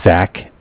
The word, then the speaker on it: Zach: My name is Zach, from, spelled Z-A-C-H, I'm sorry.